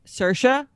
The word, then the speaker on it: Saoirse: Saoirse? – Yes. – Saoirse Ronan? – Yeah. – I was like waiting for you to say it. Like she's going to get to Saoirse Ronan.